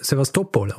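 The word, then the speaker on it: Sewastopol: Übrigens, jemand hat mir auch geschrieben und hat mir gesagt, dass ich äh, Sevastopol falsch ausgesprochen habe. – Mhm. – Weil das spricht man wohl äh, Sevastopol aus.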